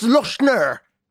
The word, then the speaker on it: Lochner: I'd like to think that the only name you mispronounced is Stefan Lochner. He's like, it's Lochner.